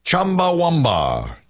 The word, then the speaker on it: Chumbawamba: But look how it's spelled. The Wamba is W-A-M-B. It's Chumbawamba. I guess that's really... Is that how you say it, Chumbawamba? – Probably is Chumbawamba, but we always say Chumbawamba.